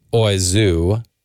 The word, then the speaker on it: oiseau: There's an article in the Columbia Journalism Review that says that we don't know for sure, but that it might come from a French word for bird, which is pronounced oiseau, although it's not spelled like oiseau. It's like O-I-S-E-A-U, but pronounced oiseau. Pretty close.